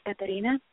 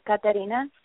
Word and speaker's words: Caterina: My name is Katarina. Thank you both for taking my call. – Can you say your name again or spell it for us? – It's sure. Katarina, C-A-T-E-R-I-N-A. – C-H-E? – C is in cat, A-T. E-R, Katarina. – I-N-A.